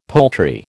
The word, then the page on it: poultry: American English